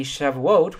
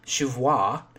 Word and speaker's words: Shavuot: Shavuot, or maybe Shavuot, which I originally pronounced a few weeks ago as the French might, as a uh, Chevois, is the ninth big holiday.